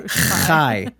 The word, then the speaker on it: chai: This is the Jewish pie necklace. It was Jewish chai necklace. Say it right, chai. – Is that how it's spelled though? – No. – It looks like a pie C-H-A-I.